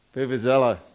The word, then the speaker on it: vuvuzela: Vuvuzela, thank you.